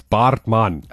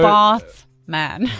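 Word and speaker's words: Baartman: Yeah, you so butchered that surname. It's Bartman. – Okay, well, all right. – So Bartman.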